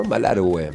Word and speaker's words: Malargüe: Malargüe, está bien, ¿no? Malargüe, así se pronuncia.